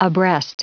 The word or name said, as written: abreast